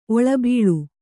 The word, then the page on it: ಒಳಬೀಳ್: oḷabīḷ